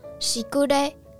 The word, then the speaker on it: 時雨: 时语呢就念作“ 西古嘞，西古嘞”。